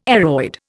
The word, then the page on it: aroid: Indian